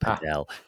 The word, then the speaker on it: Padel: I've recently been playing a game called Padel, I think is how you pronounce it. I say paddle, but the Europeans call it Padel.